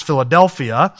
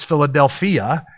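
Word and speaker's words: Philadelphia (φιλαδελφία): And I didn't get the accent on the wrong syllable with that, like we would pronounce Philadelphia. They actually accent that wrong. It's Philadelphia.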